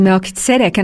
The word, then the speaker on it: Melchizedek: And the other thing I want to understand is, how do you say it? Because I say Melchizedek, but I just heard you say it differently. So is there an official way? – I'm fairly sure that it's Melchizedek.